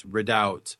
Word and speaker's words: redoubt: That's with a silent B, redoubt, redoubt.